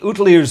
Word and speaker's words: Outlier: "The second reason is to show you my latest photographic project called the Outlier Zone. Outlier is pronounced as a Scot would pronounce outlier, but with an oot.